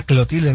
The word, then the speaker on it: Clotilde: Doña Cleotilde. – Era Clotilde, ¿no? Acá es Clotilde.